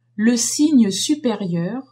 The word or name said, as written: signe supérieur